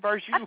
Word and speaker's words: Vargu: It was Ryan Vargu, but that's really good. – Vargu? – Good. – Oh, yeah. – I'm filled it, like rhymes with ragu.